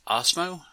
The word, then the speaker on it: Osmo: The first one he lists is I believe it's pronounced Osmo. – Yeah, it's OSMO, and it's available in the Ubuntu repositories.